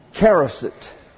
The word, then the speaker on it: charoset: Charoset. It's spelled many different ways. This transliteration is C-H-A-R-O-S-E-T, charoset.